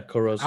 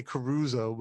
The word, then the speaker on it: Corozo: Yeah, Corozo. – I always mess it up. I say Caruso, but I know it's Corozo. So we've got some really good Corozo buttons that look beautiful, and it gives a nice contrast.